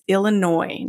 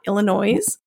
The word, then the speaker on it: Illinois: S on the end of that state name, but we do not pronounce it. It is Illinois, not Illinois.